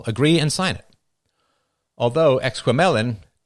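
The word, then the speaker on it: Exquemelin: All right, I looked up how to pronounce this, Exquamelin? No, it's Exquamelin,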